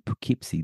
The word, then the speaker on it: Poughkeepsie: You're originally from Poughkeepsie. Do I pronounce this right? – You did pronounce it correctly.